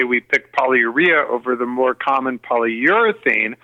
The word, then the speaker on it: polyurea: Did you say urea? – Polyurea. And are you sure that you're saying that right? Polyurea? – Yep.